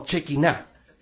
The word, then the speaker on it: Shekinah: Quizás no es la traducción o la pronunciación más correcta. Algunos le dicen la Shekinah. ¿Ya? Shekinah. No, en serio. Shekinah. Pero nosotros le decimos como bien acampado, Chequiná, ¿ya? Pero no nos preocupemos de, son lenguas muertas que no sabemos cómo se pronuncian.